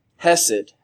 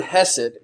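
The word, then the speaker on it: chesed: This word loving kindness is a translation of the Hebrew word hesed, and it's actually pronounced with a hard C, and you'll see it spelled sometimes in different texts and in different tools that we use looking at the Hebrew language with a C, C-H-E-S-E-D. And the pronouncing of the word is something that I'm not going to do correctly this morning because I'm American, I'm not Hebrew.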